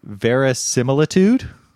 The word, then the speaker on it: verisimilitude: but verisimilitude? – Yeah. I've heard this word. I also don't know how to pronounce it. Verisimilitude is how Google said.